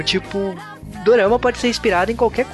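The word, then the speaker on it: Dorama (ドラマ): E uma coisa também que é interessante falar é, Dorama é a pronúncia japonesa e quando a gente fala, eu pelo menos me refiro a japonês. Porque em coreano, como que fala, Juba? Ih, cara. é, é durama.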